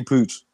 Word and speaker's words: Puig: I thought it was Puig, but it's Ricky Pooch, Pooch or something like that.